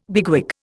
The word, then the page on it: bigwig: Indian